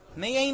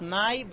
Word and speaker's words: mei: Mei mashma, the Gemara says. Notice it's not pronounced mai, but rather mei.